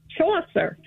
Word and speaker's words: Chaucer: Wait, how do you pronounce it? Chaucer? – Chaucer.